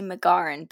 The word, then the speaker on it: McGauran: I've had, so it's Julia Giannini McGoran, but I've had Grulia McGosnan before.